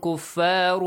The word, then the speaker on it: كُفَّارٌ: I told you to not pronounce it completely, yes? رُن, when you pronounce the noon sound, the tongue touches the gum area at the top. – Yeah. – كُفَّارٌ. Do not let it touch there. وَهُمْ كُفَّارٌ. Your tongue just goes around in the mouth, okay?